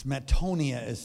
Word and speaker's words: metanoia: it's metanoia is how you pronounce it, changing a mind or a transformative change of the heart.